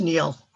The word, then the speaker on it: Niall: It's spelled Niall, but it's pronounced Neil.